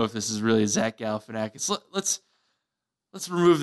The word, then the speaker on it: Galifianakis: It's Galifianakis is really how you pronounce it. – Galifianakis? – Yes. The media has got this wrong for years.